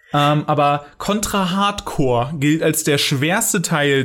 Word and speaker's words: Corps: aber es heißt Hardcore, weil das Wort Corps äh, ohne e am Ende wird Core ausgesprochen, deswegen heißt es auch Blastcore.